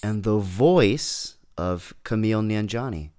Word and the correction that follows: Kumail: I totally said that wrong, didn't I? – Kumail Nanjiani.